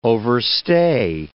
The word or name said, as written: overstay